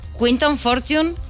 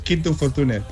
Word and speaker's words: Quinton Fortune: Quinton Fortune, ¿lo hemos pronunciado bien? – Sí, sí. Bueno, aunque normalmente en España es Quinto Fortune, pero es igual.